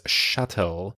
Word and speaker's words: chattel: Many people have described women in patriarchal systems as chattel, or a personal possession. – Ah, that word is not pronounced like cattle.